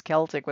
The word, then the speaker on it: Celtic: The proper pronunciation is Celtic with a hard C. And except in Boston.